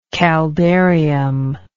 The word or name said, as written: caldarium